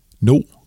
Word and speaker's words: No: No, parentes, udtales det rimer på bo, er 15 år gammel, non-binær, aseksuel og foretrækker pronomenerne de og dem.